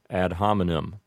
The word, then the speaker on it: ad hominem: And then, down at the bottom of the page, we have ad hominem. I suppose that a more correct Latin pronunciation would be ad hominem, but with an Americanized pronunciation, we just say ad hominem.